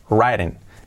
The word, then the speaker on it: writing: la R y luego la I como una E, writing, writing. Hay dos I's ahí, pero no suena writing, no suena ahí ninguna.